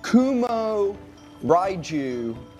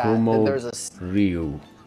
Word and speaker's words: Kumo Ryu: So you mentioned it's Kumo Raiju or something like that. – Kumo Ryu. – Right, that's the one. You nailed it. Your pronunciation was way better than mine.